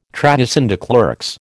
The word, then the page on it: trahison des clercs: American English